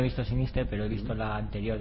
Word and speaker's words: Sinister: Yo, fíjate que tengo ganas de ver, no sé si habéis visto, mm, Sinister. sinister, bien pronunciado, Sinister.